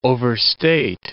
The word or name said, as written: overstate